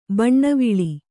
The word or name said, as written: ಬಣ್ಣವಳಿ